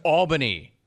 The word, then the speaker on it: Albany: Wore the rest of the country knowing that it might be spelled Albany, but it's pronounced Albany.